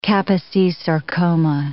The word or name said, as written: Kaposi's sarcoma